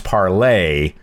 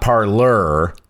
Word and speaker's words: Parler: But and apparently this is according to Nate Pickowitz, but it's pronounced parlay. – Parlay. – Not parlur. – Well, it depends on where you're at. – Which is ridiculous. – Are you pirate? – French? Because I'm French. – Or are you in your parlor? – If it was in your parlor, it would be spelled P-A-R-L-O-R. But it's spelled P-A-R-L-E-R.